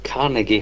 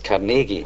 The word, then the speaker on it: Carnegie: A lot of people over here talk about Carnegie Hall, but in Scotland, for some reason, we always put the accent on the second syllable, so we refer to it as Carnegie Hall.